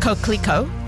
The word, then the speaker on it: Coquelicot: It is pronounced Coquelicot.